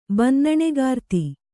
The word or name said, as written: ಬಿನ್ನಣಗಾರ್ತಿ